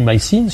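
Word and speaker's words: My Scene: Donc, ils ont essayé de riposter en sortant une nouvelle série qui s'appelle Mycène ou Mycine, si on le prononce en anglais.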